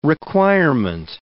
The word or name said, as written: requirement